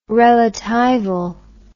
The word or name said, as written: relatival